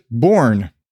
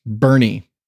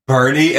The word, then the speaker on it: Boerne: It's spelled B-O-E-R-N-E or some shit like that. I pronounced it Bourne. – Yeah, that sounds like Bourne. – Apparently, it's pronounced Bernie. – What? – I actually was like, that I bet there it's like Burn or something. – Yeah. – Bernie, and there's not even an R in it.